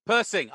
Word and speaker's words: Persing: Persing or Pershing? – Persing. – I got it right.